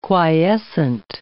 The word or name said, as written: quiescent